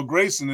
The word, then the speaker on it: Greason: Everybody says Grayson, but you know Grayson is the right one.